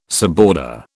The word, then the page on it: suborder: British English